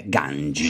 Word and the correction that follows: kanji: sì. – Kanji. – Ah, kanji. Kanji.